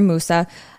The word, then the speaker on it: Musa: Mansa Musa's personal name is Musa or Musa. I've heard it said different ways, so I sort of just say Musa because that's my fine English pronunciation.